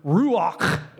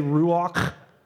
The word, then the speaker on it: ruach: In fact, when you get into the Greek and Hebrew meaning for the word Spirit, you will find that the Hebrew word ruach, everybody say ruach. You have to get the loogie at the end. That's actually how it's pronounced. Ruach and pneuma both mean wind and spirit.